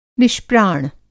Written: निष्प्राण

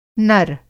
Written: नर

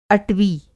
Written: अटवी

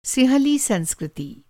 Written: सिंहली

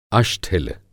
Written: अष्ठिल

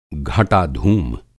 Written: घटा धूम

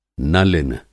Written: नलिन

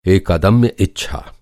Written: अदम्य

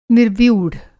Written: निर्व्यूढ़

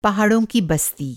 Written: बस्ती

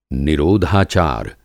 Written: निरोधाचार

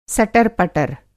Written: सदर-पटर

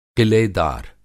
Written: क़िलेदार

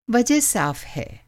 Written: साफ़

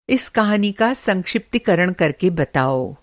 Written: संक्षिप्तीकरण

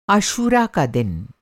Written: आशूरा

